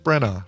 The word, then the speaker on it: Branagh: You put the you don't pronounce the GH. Kenneth Branagh, right? – Branagh.